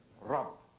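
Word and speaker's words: Rabb (رب): Actually, Rabb is really one syllable, isn't it? Rabb. When you say the Rabbun is two syllables, when you pronounce the sign at the end of the letter, but it's actually Rabb, one syllable letter, but so rich in meaning.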